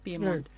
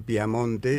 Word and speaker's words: Piemonte: Claro, por eso no es Pia, sino que es Pie. – Piemonte, claro. – El nombre formal. Lo que pasa que pronunciado en piemontés es una E abierta. Entonces parece que dijeran Piamonte y, bueno, hay un conflicto larguísimo.